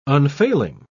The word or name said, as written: unfailing